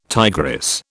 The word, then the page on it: tigress: British English